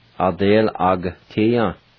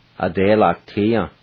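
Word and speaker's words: adelagatiya: The G-A, the A is silent. And you pronounce it just like this. You sound at the G sound. Adel-ag-tia. Adel-ag-tia.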